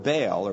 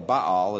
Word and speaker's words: Baal: Now, the word Baal or Baal is the better pronunciation in Hebrew. It's just harder to say. So we say Baal.